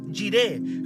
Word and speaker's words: Jireh: Hoy día usted no va a decir Dios, hoy día usted va a decir Jiré. Y va a salir a levantar su mano, va a decir Jiré. Yo quiero que usted le diga Jiré al Señor. ¿Cómo se escribe Jireh? E J-I-R-E-H. E Jireh. J-I-R-E-H.